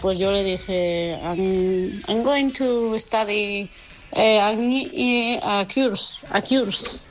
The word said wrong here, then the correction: course: Porque no, un curso no se dice cures, se dice course.